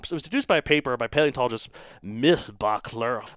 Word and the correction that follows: Mihlbachler: Look, it's Mihlbachler. You try to pronounce that.